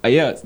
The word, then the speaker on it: Ayat: Ayat.